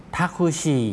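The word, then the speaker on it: ท าคุชิ (タクシー): ทาคุชิทาคุชิรถแท็กซี่นะครับออกเสียงช้าช้าจะออกเสียงว่าทาคุชิอิทาคุชิอิตรงชิอิออกเสียงรวบยาวนะครับ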